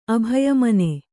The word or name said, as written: ಅಭಯಮನ